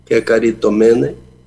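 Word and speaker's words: Kekaritomene (κεχαριτωμένη): para você pegar as sílabas aí, Kekaritomene, Kekaritomene.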